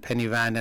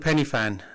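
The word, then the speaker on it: Pen y Fan: I think there was a whole round of Penny Fan and Penny Van and all sorts of various different pronunciation of it. – So what is a pronunciation? – I've been told it's called Penny Fan.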